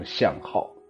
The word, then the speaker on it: 向滈: 它不管是从音韵来说，还是从意思来说，我觉得它应该读成向号。